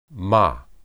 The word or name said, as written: mah